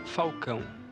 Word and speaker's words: Falcão: Il nome é mio si pronuncia Falcão, però eh, como come il tifoso romanista eh, me mi chiama di Falcao, dessa eh, adesso mi chiama Falcao.